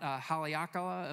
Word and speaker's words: Haleakala: When we were in Hawaii just recently, we went to the top of Mount Haleakala. Am I saying that right? Yes, thank you. I'm saying it right.